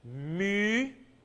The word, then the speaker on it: mu: And this is the equivalent of U, mu, U. Like the U umlaut. Now some of you, like Steve, have had some German before, and you know how to pronounce that. Mü, mü, good.